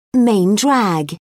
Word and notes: main drag: British English

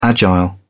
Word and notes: agile: British English

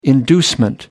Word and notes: inducement: American English

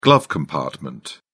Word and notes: glove compartment: British English

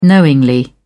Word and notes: knowingly: British English

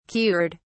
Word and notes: cured: British English

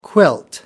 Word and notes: quilt: American English

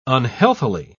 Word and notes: unhealthily: British English

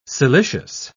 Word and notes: siliceous: British English

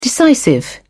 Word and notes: decisive: British English

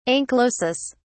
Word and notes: anchylosis: British English

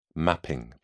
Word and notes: mapping: British English

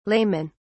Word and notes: laymen: British English

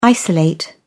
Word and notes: isolate: British English

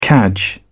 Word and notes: cadge: British English

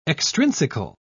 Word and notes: extrinsical: British English

